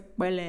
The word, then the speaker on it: кпелле: Он называется «кпелле», там сначала «к», потом «п» а, а на самом деле он называется Кбеле. Э. Вот там такое одновременно «б» произносится.